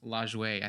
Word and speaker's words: Lajoie: Actually, Charles, looking at the pronunciation, I believe it is pronounced Lajoie.